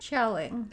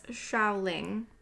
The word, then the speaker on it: Xiaoling: Zhu Zhuling? – Xiaoling. – Xiaoling? – Xia, like, it's like S-H-A, I think. Like. – Okay, everyone. I'm really sorry if I'm pronouncing this incorrectly. I believe it's Xiaoling, but she was a badass.